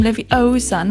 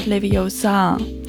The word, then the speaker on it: Leviosa: So, und dann sagt sie: Besides, you're saying it wrong. It's Wingardium Leviosa, not Leviosa.